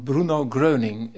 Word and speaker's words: Bruno Gröning: De vriendenkring van Bruno Gröning. Wie is Bruno Gröning? – Nou, allereerste complimenten voor de uitspraak. Heel goed. Gebeurt niet vaak dat iemand dat in één keer kan zeggen.